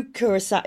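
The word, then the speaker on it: curaçao: And they were drinking blue curacao. Is it curacao? I never know how to say it. Curacao? – Yeah, yeah.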